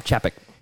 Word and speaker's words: Chapek: Chapek. – Bob Chapek.